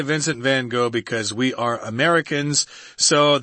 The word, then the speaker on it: van Gogh: But the one who's the most popular from Holland is probably Vincent van Gogh. Or Gough. – Yeah, if you're in his own native country, you would actually say Van Gogh. But Americans say Van Gogh, and we're going with that, guys.